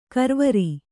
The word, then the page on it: ಗರವರ: Kannada